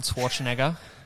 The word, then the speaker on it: Schwarzenegger: How do you say his last name? – Arnold Schwarzenegger. Schwarzenegger.